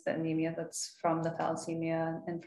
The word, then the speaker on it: thalassemia: And I've also I've also had clients um, at work that have talamacina. Tal- am I saying that right? Talamacina. Uh, um, talamacina. Am I? – Oh, thalassemia. Thalassemia. Yeah, thalassemia yes.